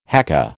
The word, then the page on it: haka: American English